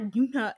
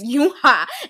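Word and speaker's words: Yunha (윤하): It's not Yuna. It's Yunha.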